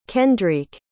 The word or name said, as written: kendriek